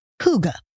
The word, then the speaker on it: hygge: Okay, I'll say that again, hygge. I've heard it pronounced all sorts of ways, but we'll go with that one. It's spelled H-Y-G-G-E, hygge, And it's defined as a kind of coziness and conviviality that makes you content and grateful to be where you are.